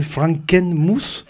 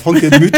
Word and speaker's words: Frankenmuth: Et c'est Frankenmuth. – Frankenmuth. – Voilà, Frankenmuth.